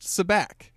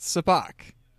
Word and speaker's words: Sabacc: It's not pronounced Sabacc. That's how I've always pronounced it. It's pronounced Sabacc, the card game that they play.